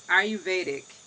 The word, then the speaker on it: Ayurvedic: And so when we can just jump right in and you can tell us, I keep from trying to pronounce Ayurvedic.